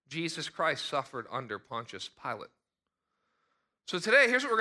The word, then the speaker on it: Pilate: Well, if some of you, when you read Pilate, you may think Pilates. No, they're spelled the same, okay? I've suffered under Pilates. This is different. This is suffering under Pontius Pilate, okay?